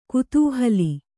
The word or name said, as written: ಕುತೂಹಲಿ